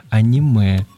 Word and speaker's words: аниме: Кстати, та же самая Александра Артемьева, которая спросила тебя про гик и исправила тебя. Аниме. Ударение написала она, здравствуйте. – Аниме. – Да, на самом деле, да, аниме, так что смиритесь, мы тоже когда-то смирились.